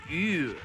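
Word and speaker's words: Ewww: That was a song called E-W-W-W. I think it's pronounced U, like a sound of disgust.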